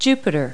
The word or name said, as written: Jupiter